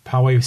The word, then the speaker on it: Poway: Okay, that the PCPA, Poway Center for the Performing Arts, I said that right. – Okay, yeah. PCPA.